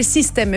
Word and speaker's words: Systaime: Enfin, voilà, il se réinvente et ça s'appelle Système. Mais pas Système comme un système, Système comme Je T'aime. Enfin, ça s'écrit Système, S-Y-S-T-A-I-M-E.